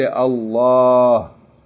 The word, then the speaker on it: Allah: Many times you'll see this written on top of the shadda. That means that you should pronounce it as though there's a long vowel, aleph, with that letter lamb. So you say Allah. Okay? But there's no aleph written in the word itself, although it has to be pronounced.